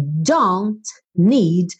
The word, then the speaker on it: don't need: Aí preste atenção aqui, olha, don't need. O T ela não falou.